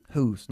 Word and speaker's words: whose: Y ojo de no pronunciar whose con una J fuerte, ¿ok? Es huh. – Mm-hmm. – Whose, no whose, ¿ok? – Okay.